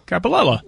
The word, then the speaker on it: Coppolella: Coppolella.